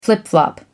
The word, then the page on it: flip flop: American English